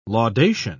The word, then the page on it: laudation: British English